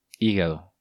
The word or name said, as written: Higado